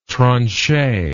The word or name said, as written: tranchet